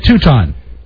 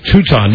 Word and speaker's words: Teuton: Teuton. – Okay, Teuton.